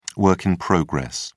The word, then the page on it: work in progress: British English